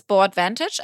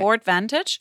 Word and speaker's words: Board Vantage: Das Board Vantage, also wie das Board, B-O-A-R-D. – Und dann wie Advantage. – Wie Advantage ohne das A, genau, okay. Board Vantage.